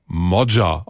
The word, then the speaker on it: Magyar: Sein Name schreibt sich zwar Peter Magyar mit Y, der Nachname wird aber Modja ausgesprochen.